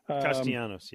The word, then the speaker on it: Castellanos: Castellanos, yeah.